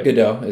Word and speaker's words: Gadot: Um, I don't know how to pronounce her last name, if it's Gal Gadot or Gal Gadot or whatever. – I think Gadot is right. Yeah, that's how I would say it.